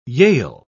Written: yale

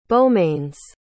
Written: beaumains

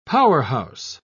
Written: powerhouse

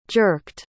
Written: jerked

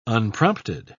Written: unprompted

